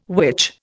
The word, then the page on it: which: Indian